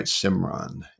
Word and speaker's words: Simran: I am Simran, S-I-M-R-A-M. Did I say that right? Simran, S-I-M-R-A-N, N as in Nancy.